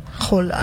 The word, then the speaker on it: Haaland: Bueno, aunque dicen que se pronuncia Haaland, ¿no? – Haaland.